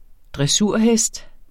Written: dressurhest

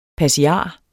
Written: PASSIAREN

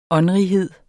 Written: åndrighed

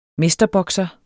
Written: mesterbokser

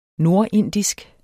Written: nordindisk

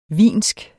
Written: wiensk

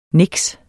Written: niks

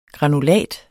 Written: GRANULATS

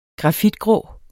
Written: grafitgrå

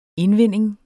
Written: indvinding

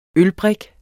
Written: ølbrik

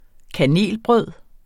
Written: kanelbrød

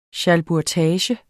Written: SCHALBURGTAGE

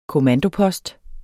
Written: kommandopost

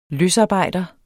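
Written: løsarbejder

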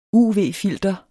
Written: uv-filter

